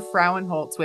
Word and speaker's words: Frauenholtz: Today, I have Amber Frauenholtz with us.